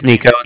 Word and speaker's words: Niko: Again, my name is Nico. It's spelled N-I-K-O. – Okay. – And the Bank of Cardiff is spelled C-A-R-D-I-F-F, as in Frank.